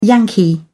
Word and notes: yankee: British English

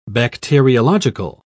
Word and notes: bacteriological: British English

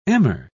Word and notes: emmer: British English

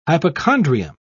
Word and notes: hypochondrium: British English

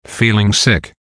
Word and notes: feeling sick: American English

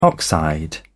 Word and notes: oxide: British English

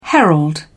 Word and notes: herald: British English

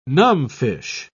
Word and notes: numbfish: British English

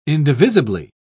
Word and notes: indivisibly: British English

